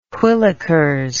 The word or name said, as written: whillikers